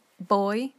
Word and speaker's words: voy: So to conjugate ir, because it's unfortunately an irregular verb, classic, it's for I go is voy, but remember in Spanish, V's are pronounced as b, so boy. You go bas, V-A-S.